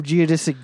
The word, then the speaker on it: geodesic: So, speaking of geodesic domes, am I saying that right? – Yeah, of course you are.